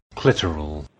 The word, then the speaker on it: clitoral: Thank you for the heads up that I have been mispronouncing clitoral all my life. And I shall now make an effort to use the correct pronunciation, which is, of course, clitoral.